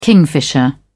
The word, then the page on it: kingfisher: British English